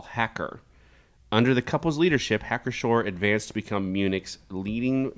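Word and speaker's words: Hacker-Pschorr: The name Hacker Shore. So it's Hacker dash Pschorr. And I would not have known how to pronounce this unless Mr. Spees told me how to pronounce it.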